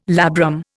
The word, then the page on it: labrum: Indian